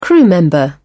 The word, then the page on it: crew member: British English